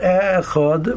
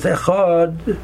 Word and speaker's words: Echad: So the word Echad has two syllables. The correct way to pronounce it is on the last, is to put the stress on the last syllable. So it's not Echad, but it's Echad.